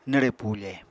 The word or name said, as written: nwre pufe